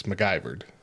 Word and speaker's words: MacGyvered: You pronounce that weird. – MacGyvered? – You don't hit the mic so hard. It's MacGyvered.